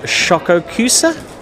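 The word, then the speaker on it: Schokoküsse: The pronunciation of Schokokussi? – Yes, that's correct.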